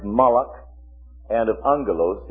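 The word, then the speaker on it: malach (מלאך): Now, in the Hebrew, the word for angel is malach. All right, malach. M-A-L. Two dots means the end of a syllable. This is a silent letter. This is A. And this is a kind of a KH sound.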